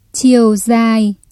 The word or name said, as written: chiều dài